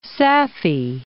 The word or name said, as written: Safi